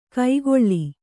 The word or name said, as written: ಕೈಗೊಳ್ಳಿ